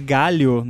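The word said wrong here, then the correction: gálio: É, galio, né? Não é galho.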